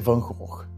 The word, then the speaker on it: Van Gogh: É Van Gogh.